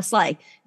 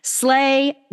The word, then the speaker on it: SLAAI: And I hope I'm pronouncing this correctly, S-I-A-A-I Makeup Melting Butter. Okay, I didn't even try to pronounce it. Or is that S-L? Oh, Slay. Never mind. Slay. S-L-A-A-I Makeup Melting Butter Cleanser by Drunk Elephant.